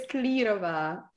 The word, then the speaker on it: Klírová: How do you say your last name? – It's Klirova.